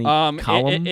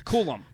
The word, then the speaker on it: Coulomb: You know, now that Danny Colombe, I believe, is that how you say it? Danny Colum? – It's Coulomb.